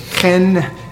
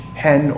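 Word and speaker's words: chen (חן): It's chen with a guttural H sound. It's C-H-E-N with a guttural H, hen or hain.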